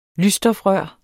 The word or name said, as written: lysstofrør